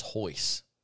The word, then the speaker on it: Royce: I didn't know it was Hoyce. I, I knew who Royce Gracie was. I didn't know Hoyce.